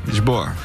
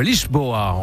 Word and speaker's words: Lisboa: Lisboa. – Oui, exactement, le Lisboa.